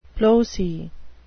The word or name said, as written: ploce